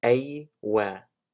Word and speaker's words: aywa: You say aywa, aywa, aywa, aywa, aywa, aywa. So aywa is how you say yes, and it's very common in Egyptian slang.